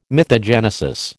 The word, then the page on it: mythogenesis: American English